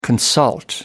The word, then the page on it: consult: American English